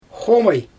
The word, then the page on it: xomaj: Xyzyl